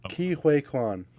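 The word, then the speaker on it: Ke Huy Quan: Ki Huy Quan.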